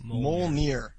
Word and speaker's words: Mjolnir: And one more thing from the mailbag I have is that Fulkin says that Mjolnir is pronounced Mjolnir. – Mjolnir.